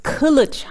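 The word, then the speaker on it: Kılıç: Und im türkischen Alphabet, da gibt es das I ohne Punkt. Und also wohl Asli, also Asle wird ohne I-Punkt geschrieben und Kilic auch ohne I-Punkt Kilic.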